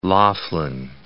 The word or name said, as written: Laughlin